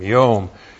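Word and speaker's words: yom (יוֹם): That's Y long O-M, yom.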